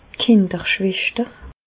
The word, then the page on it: d' Kinderschweschter: Bas Rhin; Reichshoffen